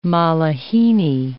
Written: malihini

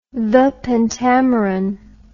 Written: pentameron the

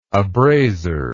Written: abraser

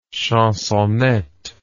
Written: chansonette

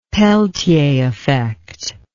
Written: Peltier effect

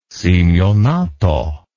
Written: simionato

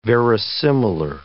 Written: verisimilar

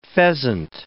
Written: pheasant

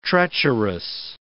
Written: treacherous

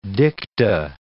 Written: dicta

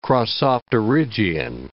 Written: crossopterygian

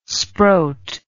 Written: sproat

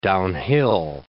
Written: downhill

